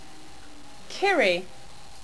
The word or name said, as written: Kyrii